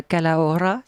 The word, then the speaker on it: Calahorra: le château de la Calahorra, c'est ça? – Oui.